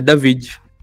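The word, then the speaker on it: Daveed: De novo, não sei pronunciar o nome do cara, David? David? – É David.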